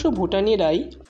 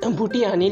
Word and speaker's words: ভুটিয়ানি: ভুটানিরাই পাথর বহন করে এই পরিশ্রমের কাজ পুরুষেরা কিন্তু করে না যদিও ভুটানি উচ্চারণটা ঠিক না লেখার সময় ভুটা ভুটিয়ানি লেখাটাই উচিত যেহেতু টেক্সটে ভুটিয়ানি আছে